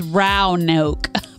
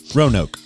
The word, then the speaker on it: Roanoke: And so what happens is areas and regions pick up certain vernacular and articulation of words and vowel sounds that it's Roanoke for the natives. – Roanoke? Or is he saying Roanoke? – You leave the A out. It's a two-syllable word. – Okay. – Roanoke.